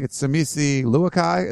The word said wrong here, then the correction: Luakai: Is that how you say his name? – Luaki. – Sorry, my bad.